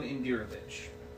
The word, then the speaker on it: Indirovich: Koleon Indirovich. K-O-L-Y-A-N. – Yep. – Indirovich. I-N-D-I-R-O-V-I-C-H. – V-I-C-H? – Yes.